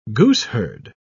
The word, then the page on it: gooseherd: British English